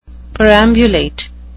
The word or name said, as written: perambulate